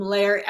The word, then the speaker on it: Lehr: And then those of you who are listening to this on the podcast version, Leslie's name is spelled L-E-S-L-I-E, last name Lair, L-E-H-R. So if you're looking for her, the last name is L-E-H-R, and she's easy to find.